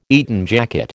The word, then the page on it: Eton jacket: American English